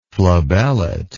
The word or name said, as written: flabellate